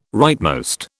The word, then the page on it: rightmost: British English